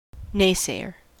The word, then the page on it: naysayer: American English